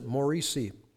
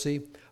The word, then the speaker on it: Morsi: As Americans everywhere stopped to celebrate Thanksgiving Day, Egyptian President Morsi, I believe that's how it's pronounced, Morsi, appeared on Egyptian television and shocked the nation, issuing a presidential decree effectively banning all challenges to his decrees, laws, and decisions.